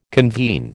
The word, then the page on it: convene: American English